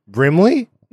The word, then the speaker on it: Brimley: Brimley? – No, you're saying it right.